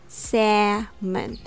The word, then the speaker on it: salmon: First of all, salmon, the fish, has the typical letter A sound, a eh, salmon.